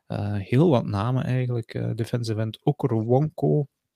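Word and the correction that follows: Okoronkwo: Die naam heb ik waarschijnlijk verkeerd uitgesproken, dus ik ga dat niet nog eens proberen. Okoronko, ja, inderdaad. Oko... – Okoronko. – Okoronko.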